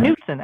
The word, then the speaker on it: Knudsen: Knudsen, actually. – Knudsen. – Yeah, huh? It's Viking. It's Norwegian. So you always pronounce the K.